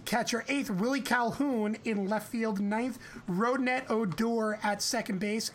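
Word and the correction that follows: Rougned: I can't believe I pronounced that correctly. Rotation as follows. – You didn't. – It's Runyed.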